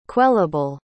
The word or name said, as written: quellable